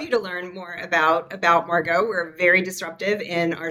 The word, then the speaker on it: Maergo: So Maergo has a secret E in it. So it rhymes with cargo, but it's Maergo.